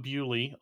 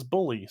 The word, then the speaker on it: Bewley: Uh, so I am Dom Bewley, also a German mm-hmm. last name, often mispronounces bully.